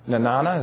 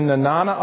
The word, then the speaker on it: Nenana: I hope I said that right, Kim. Nenana, is that how you say it? Okay. Phew. She's from Alaska. The nearest serum was in Nenana, Alaska, 1,000 miles of frozen wilderness away.